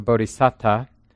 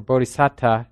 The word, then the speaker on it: bodhisatta: He says, "It is said that in the course of his long training for enlightenment over many lives, the bodhisattva, or the bodhisatta, can break all the moral precepts." So bodhisattva or bodhisatta, two ways to pronounce the word, that's the somebody on the way to being a Buddha.